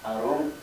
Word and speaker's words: arum: Първата дума arum с ударение на u.